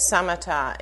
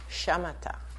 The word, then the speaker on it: Samatha: S-A-M-A-T-H-A. – T-H-A. Unless you spell it. Shamatha, right? S-H or S. A. I'm starting to depend on you for Sanskrit eyes. S-A-M-A-T-H-A. Samatha is good. Sometimes you hear people say it Shamatha because they're saying it more the Sanskrit way.